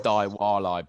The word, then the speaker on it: Diwali: It's pronounced Diwali, bruv.